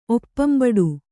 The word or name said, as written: ಒಪ್ಪಂಬಡೆ